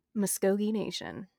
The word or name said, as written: Muscogee Nation